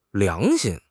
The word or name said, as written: 良心